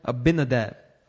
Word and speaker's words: Abinadab: Am I saying his name right? Abinadab. Yeah, sounds good.